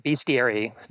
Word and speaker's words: bestiary: doing a translation of a 12th century, and I'm going to say the word, is it pronounced bestiary or bestiary? I've always been a little unclear on that. Like, it's primer and primer. – We usually say bestiary these days.